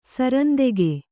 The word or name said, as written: ಸರಂದೆಗೆ